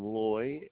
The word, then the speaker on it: Loy: Last name Loy, L-O-Y. – L-O-Y.